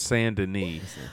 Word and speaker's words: Saint Denis: I know, and it's pronounced Saint Denis. – Well, fuck me, man. I mispronounce everything. – It's, I know it looks like Saint Denis, but it's Saint Denis.